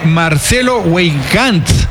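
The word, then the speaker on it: Marcelo Weigandt: El argentino procedente de Boca Juniors, nada más y nada menos, el número 57, Marcelo Weidgan, así se pronuncia.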